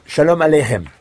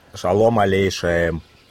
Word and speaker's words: Shalom aleichem: Shalom aleichem. – Shalom aleichem. – Oh là là. – Alors, vous comprenez? – Il connaît les prières, mais il met des O à la place des A.